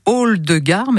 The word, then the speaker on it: Oldegar: Hall de Gare, mais ça s'écrit O-L-D-E-G-A-R.